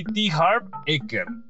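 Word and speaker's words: T. Harv Eker: Se dice así: T. Harp Eker.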